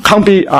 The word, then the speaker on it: 糠秕: 你观看，看见有一块非人手凿出来的石头打在这象的半铁半泥的脚上，然后把脚砸碎，于是金银铜铁泥都一同砸得粉碎，成为夏天河场上的糠，这个是怎么读啊？糠秕啊，被风吹散，无处可寻。